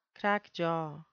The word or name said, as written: crackjaw